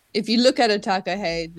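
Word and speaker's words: takahē: So, you know, anyone out there listening on the gargle, you know, it's go to find the takahe. There's an accent on the E.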